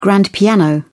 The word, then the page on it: grand piano: British English